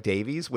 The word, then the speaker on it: Davies: I just want to give a giant shout out to a show, a British show called Man Down, which stars Greg Davies, Greg Davis, pronounced Davies. I mean, sorry, Greg Davis spelled Davies.